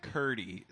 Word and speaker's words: Curdy: Curdy Shack. – Spell it. – C-U-R-D-Y. – What? Like cheese curd? – Like Jeanette Curdy from iCarly.